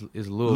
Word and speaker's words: Louisville: It's pronounced Louisville.